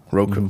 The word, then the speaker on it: Roku: Roku.